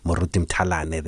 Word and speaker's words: Moruti Mthalane: At halftime of that fight, because it was an overseas broadcast, they actually advertised this fight of Moruti Mthalane. And they even got his name right, the pronunciation, Moruti Mthalane there.